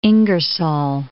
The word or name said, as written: Ingersoll